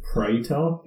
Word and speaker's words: Praetor: I think it's pronounced Praetor. It's just Predator without a D, the suit.